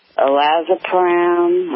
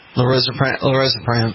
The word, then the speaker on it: Lorazepam: it's Olazepram or something. – Lorazepam.